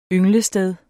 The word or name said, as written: ynglested